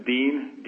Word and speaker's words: Dean: Yes, Dean, D-E-A-N, last name DeVolis, D-O-V-O-L-I-S.